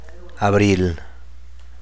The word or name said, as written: abril